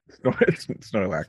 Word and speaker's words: Snorlax: fight Snorkelax. did I get the name right? – Snorlax. – Snorlax.